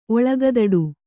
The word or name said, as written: ಒಳಗದಡು